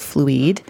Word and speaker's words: Fluide: So the other company that I'm highlighting today is called Fluide. It's spelled fluid with an E on the end. And their website is fluide.us.